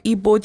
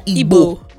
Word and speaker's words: Igbo: You are Igbo. Yeah, Igbo. – No, Igbo. – Then which one is Igbo? – The G is silent.